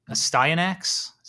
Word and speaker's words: Astyanax: And I found in the back of it was a game that Jalico put out, Astyanax.